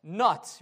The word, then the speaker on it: Nut: The first goddess was Nut. Remember that goddess a few weeks ago? Actually, I learned that the proper pronunciation is Nut, not Nut, but it's spelled Nut.